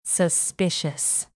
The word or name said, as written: suspicious